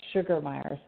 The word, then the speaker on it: Sugermeye: I think it tripped you up because it's not spelled the same, but Sugermeye.